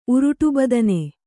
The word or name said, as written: ಉರುಟುಬದನೆ